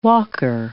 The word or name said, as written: walker